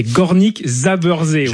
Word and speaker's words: Górnik Zabrze: C'est Górnik Zabrze, voilà.